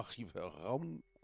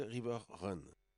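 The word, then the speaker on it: Riverrun: que je prononçais, allez savoir pourquoi River Round ou River Round, et que mon modeste niveau d'anglais m'oblige à prononcer River Run.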